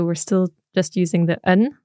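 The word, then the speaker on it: an: Oh, so it's un, the same as the masculine nouns we've learned. So un station, but also un skull. – That's right. – And that un is spelled A-N.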